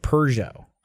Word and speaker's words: Peugeot: Probably because in Australia, they probably pronounce it the way they do in the UK, which is they pronounce it Peugeot. – Ah. – That's how Gavin says it, Peugeot.